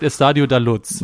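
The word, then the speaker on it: Estádio da Luz: Ähm, ja, so spricht man es nicht aus. – Äh, doch, doch, man spricht Estadio da Luz.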